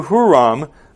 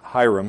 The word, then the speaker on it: Hiram: Then Solomon sent word to Huram, the king of Tyre. That can also be pronounced Hiram. In fact, I'm going to pronounce it Hiram.